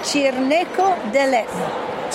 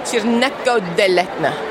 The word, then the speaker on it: Cirneco dell'Etna: We say Cirneco dell'Etna. – Cirneco dell'Etna.